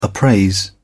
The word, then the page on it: appraise: British English